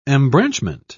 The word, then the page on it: embranchment: British English